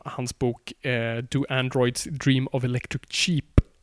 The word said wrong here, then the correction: sheep: Det är sheep, cheap, det är svårt att uttala. Alltså inte som billigt, utan som får.